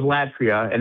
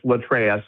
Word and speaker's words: latreia: I thought it was Latria, and now I found out it's Latreia, so I've been saying it wrong the whole time.